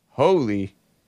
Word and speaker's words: Holi: H-O-L-I, right? It's pronounced holy.